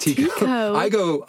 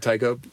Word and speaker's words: Tycho: Yeah, I think it's actually Tycho. I went to Denmark once and they're like, oh, no, it's pronounced Tycho. I pronounce it Tycho, but yeah, that was what he went by.